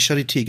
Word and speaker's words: Charité: äh, ist Professor für Virologie, ich hoffe, das ist richtig so ausgesprochen, von der äh, ich, wie heißt das, Charité in Berlin? Ich glaube Charité, ne? Die Charité, genau.